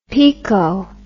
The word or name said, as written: pico